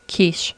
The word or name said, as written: quiche